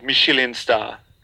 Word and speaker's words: Michelin: Yeah, that's the way you say it. Michelin star. That's how you say it.